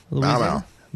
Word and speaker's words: Mamou: I don't know how to pronounce this. M-A-M-O-U. Mamau? – Mamau. – Mamau. Mamau, Louisiana.